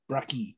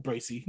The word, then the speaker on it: Bracey: Bracky. No, Bracey. No, you're right.